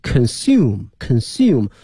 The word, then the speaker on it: consume: of、啊、这个词做 term T-E-R-M 后面加一个复数 S， in terms of consumption、啊、消费来讲，这个词我们原来讲过、啊、这里面给大家再拼一下 C-O-N-S-U-M-P-T-I-O-N consumption。同时啊，同学们注意啊，这个词它的动词啊，它叫做 consume 中间这个 U 呢发成了 U consume，而在名词里面发成了这个 I in consumption，